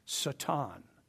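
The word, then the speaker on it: Satan (שָׂטָן): And it translates into English S-A-W-T-A-N. And it would be pronounced Satan.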